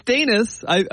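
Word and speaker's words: Daniss: It's Danis. We know that, and actually the NBA has a little pronunciation guide, which you can listen to the guy say, and it says Danis. So, yes, Danis is correct.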